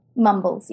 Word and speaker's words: Mumbles: Now, is this pronounced Mumbles? – Mumbles, yes. It is English.